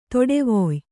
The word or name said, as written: ತಡವಾಯ್